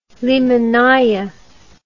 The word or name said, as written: limonnaya